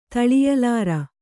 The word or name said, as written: ತಳಿಯಲಾರ